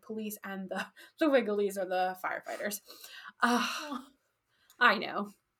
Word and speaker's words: Vigiles: By the first century CE, the Praetorian Guard, the urban cohorts, and the, I will pronounce it Vigiles because that's how it's spelled, but it's pronounced Wigiles in Latin, were added to the list of those receiving grain doles.